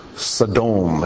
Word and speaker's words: Sodom: S-D-M. Sodom is how it's pronounced in Hebrew.